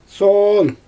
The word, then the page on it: soon: Xyzyl